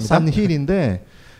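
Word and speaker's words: San Gil: 아 스페인어라서 힐로 발음을 하시거든요. – 아, 싼 힐인데 그래서 뭐 패러글라이딩 같은 경우 아니 저 호수에 살 수도 있잖아.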